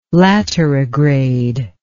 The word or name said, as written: laterigrade